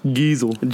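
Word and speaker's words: Geisel: Dr. Seuss's real name is Theodore Seuss Giesel. – Giesel? What the fuck? – It's spelled like diesel, but with a G. – G shit. – What a G. – Giesel.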